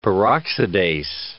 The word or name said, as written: peroxidase